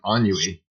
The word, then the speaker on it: ennui: But, you know, I was talking yesterday with Dr. Green, and there's a word its French origin, ennui, that Schopenhauer used for chronic boredom. – Was that ennui? – Ennui. E-N-N-U-I. – I've heard it pronounced ennui.